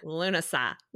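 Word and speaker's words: Lughnasadh: So Midsummer, and then there's Lammas or Lunasaw, which looks like Lugashagawa when you see it written. – it's a bunch of Gaelic letters that say Lunasaw.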